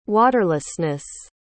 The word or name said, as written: waterlessness